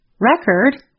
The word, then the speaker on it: record: When we stress the first part of the word, record, it is a noun. When we stress the second part of the word, record, it is a verb.